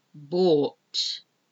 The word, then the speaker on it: bough: I'm pronouncing them hopefully clearly enough that you can hear and understand the O-U-G-H-T. Now we go on to O-U. And the sound for this is owl. It's not spelled O-W, it's spelled O-U-G-H, O-U-G-H. We've got bough, B-O-U-G-H, the bough of a tree. Plough, P-L-O-U-G-H, plough.